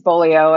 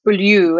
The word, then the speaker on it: Beaulieu: So my last name is Beaulieu, and I was explaining to Drew, it's very Americanized because it actually is French. It would be pronounced Beaulieu, and it means beautiful place. And people always mispronounce my name, Beaulieu, and say different things like that. So I would correct them and say, no, it's Beaulieu.